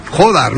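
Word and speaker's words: Jódar: Jodar, ¿no? Ese acento en la O.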